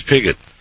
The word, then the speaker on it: Pigott: Would you please pronounce that for me? – Yeah, the gentleman's name was Edward Pigott. He's an English astronomer. – It is Pigott.